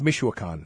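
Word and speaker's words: Michoacán: Micacon. – I think it's Michoacan. – Michoacan and Texcala.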